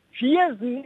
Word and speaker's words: Viersen: Schreibt man tatsächlich wie eine Vier und dann S-E-N am Ende. – Ach, Viersen. – Viersen im Rheinland. Viersen. – So wie der Pfirsich.